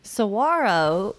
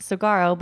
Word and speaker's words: Saguaro: Saguaro, which looks like it's pronounced Saguaro, but it's not, as well as the Petrified Forest National Park, are really unique places, and each of them are unique for their own reasons.